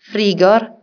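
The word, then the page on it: frigorifiru: Sardinian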